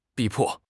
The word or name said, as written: 逼迫